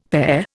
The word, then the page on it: pare: Indian